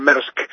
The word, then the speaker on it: Maersk: Marx, lo pronuncio fatal siempre, de marcharse a Marín. ¿Cómo se pronuncia, presidente? – Pues con Maersk. – Maersk, eso. Yo me empeño en decir Marx y es Maersk.